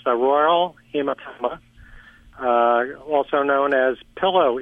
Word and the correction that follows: aural: And just for those that maybe can't hear real clearly, oral hematoma, it's spelled A-U-R-A-L, and it basically means swelling of the ear.